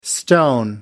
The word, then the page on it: stone: American English